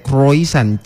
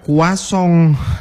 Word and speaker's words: croissant: Sama kayak croissant. Itu kan kalau di tulisannya dibacanya itu kayak croissant. Tapi pas diucapin ternyata beda, kuasong. Aduh, kalau di Indonesia mah bukan kuasong, kuah asam, kuah bugis, kuah sup itu.